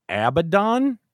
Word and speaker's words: Abaddon: Was that Abaddon? – Correct. – Is that how you say it? – That is how you say it.